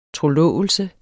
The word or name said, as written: trolove